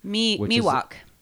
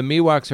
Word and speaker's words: Miwok: Did you know the name Ewoks was inspired by, I hope I'm pronouncing this correctly, the Miwoks. – Miwok. – Is it Miwok? – I happen to know a bit about Miwoks.